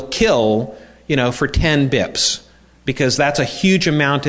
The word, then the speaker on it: bps: So if you ever, and sometimes it's abbreviated BIPS, BPS, and pronounced BIPS.